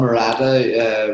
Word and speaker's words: Murata: Probably a pronunciation wrong there, no, but... – No, that's just spot on. Murata, Ryota Murata.